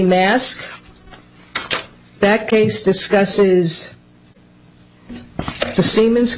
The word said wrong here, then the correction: Maersk: Did you mean Maersk? – Maersk, I'm sorry, Your Honor, yes. I pronounced it incorrectly.